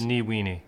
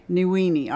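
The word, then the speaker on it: Ní Mhaonaigh: Mairead Niwini. – Niwini. All right. Yep. All right. Julie was right. She said that's how she thought it should be pronounced. I guess in English it's Mooney. – Yeah, yeah. It has an H after the M, so. – Right, right. – That makes it silent.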